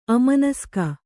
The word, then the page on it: ಅಮನಸ್ಕ: amanaska